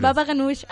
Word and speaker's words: baba ganoush: Baba ganoush.